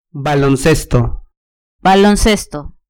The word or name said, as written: baloncesto